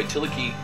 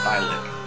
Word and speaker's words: Thielicke: By the way, Tillich, I don't think I mentioned it last time, is spelled T-H-I-E-L-I-C-K-E. It looks like Thylic, but it's actually pronounced Tillich.